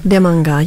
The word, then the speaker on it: Demanga: Demanga, yeah. Demanga. Usually it's with Indigenous languages or Aboriginal languages across the continent, the emphasis is always on the first syllable.